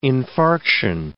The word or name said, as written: infarction